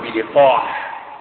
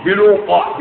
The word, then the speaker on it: بلقاح: بلقاح بلقاح بلام مكسورة بلي بلقاح مش بلوقا بلقاح بلام مكسورة فقاع بعدها يعني فحاء مهملة